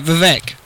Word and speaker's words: Vivek: How do you pronounce his first name? – Vivek. – Vivek? – Yeah, Vivek.